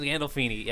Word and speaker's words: Gandolfini: James Gandolfini. – Yeah, James Gandolfini.